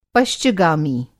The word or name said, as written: पश्चगामी